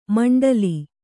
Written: ಮಂಡಲಿ

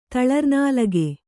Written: ತಳರ್ನಾಲಗೆ

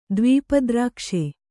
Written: ದ್ವೀಪದ್ರಾಕ್ಷೆ

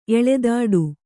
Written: ಎಳೆದಾಡು

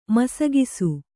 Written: ಮಿಸುಗಿಸು

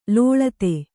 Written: ಲುಳಿತ

